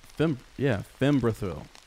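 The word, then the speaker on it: Fimbrethil: How do you say his Fembrithil? Is that right? – Fembrithil. Fembrithil. Thank you.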